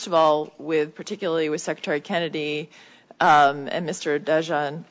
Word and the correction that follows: Jahanbani: Can you pronounce it for me? I apologize. Just I want to make sure I get it right. – Jahanbani, thank you. – Silent D, thank you.